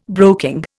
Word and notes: broking: Indian

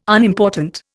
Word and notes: Unimportant: Indian

